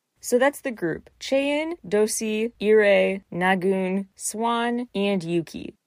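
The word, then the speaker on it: Dosie: Do-si, in Korean it's pronounced a bit more like Do-shi.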